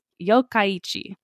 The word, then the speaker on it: Yokkaichi: In last episode, I pronounced it Yokkaichi, because I am really bad with Japanese pronunciation, but I did the how do you pronounce video. – Yeah. – It's pronounced Yokkaichi.